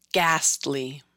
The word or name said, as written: Ghastly